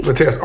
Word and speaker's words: Latarius: Latarius R. You better put the R in there.